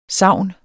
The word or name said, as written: sagn